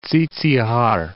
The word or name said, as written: Tsitsihar